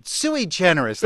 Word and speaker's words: sui generis: Well, it's sui generis, that's how we say. – With the emphasis on the sui.